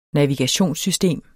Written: navigationssystem

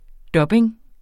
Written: dubbing

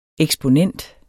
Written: EKSPONENTS

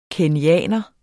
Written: kenyaner

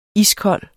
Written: iskold

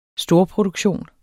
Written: storproduktion

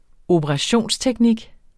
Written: operationsteknik

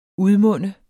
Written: udmunde

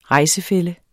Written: rejsefælle

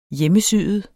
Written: hjemmesyet

